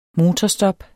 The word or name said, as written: motorstop